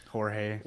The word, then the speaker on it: Jorge: But the name for me is Carlos is it George? Am I saying that right? – I don't know. Jorge. – We'll go with Carlos Jorge.